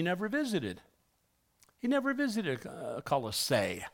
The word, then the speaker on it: Colossae: That's how you pronounce the name. If you've ever wondered, well, some people can pronounce it Colossae. They have no knowledge of how it's supposed to be pronounced. That's why, and they should have been smart enough to look it up. They didn't. It's pronounced Colossae, like you're going to say Colorado, but you Colossae.